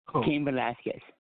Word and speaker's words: Cain Velasquez: Cain Velasquez.